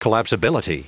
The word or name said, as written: collapsibility